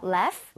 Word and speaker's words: laugh: Laugh. – Laugh? 오케이. Okay. 자, 그러면 여기 써주세요. – Laugh at... – 스펠링을 제대로 만들어주세요. – Laugh... 아하. 같은데요? – GH는 F와 비슷하게 발음이 됩니다.